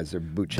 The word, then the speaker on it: Zurbuchen: Zurbuchen.